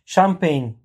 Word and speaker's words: Champagne: Champagne diye okunuyor.